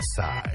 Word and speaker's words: Psy: And then in 2012, Psy was number one. – I still love the song. – The P is silent in Psy.